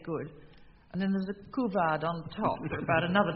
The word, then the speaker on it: couvade: And now, Anscott James, your word is couvade, C-O-U-V-A-D-E, and in spite of my pronunciation, I perhaps recognize its French in origin, couvade.